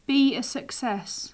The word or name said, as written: be a success